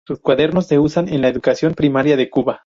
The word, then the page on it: cuadernos: espanyol